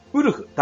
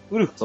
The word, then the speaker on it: WLF: ウルフ、 WLF と書いて、ウルフ。